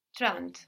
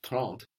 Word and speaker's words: trente: We see trente, it's spelled with an E, but we hear something more like an A in trente.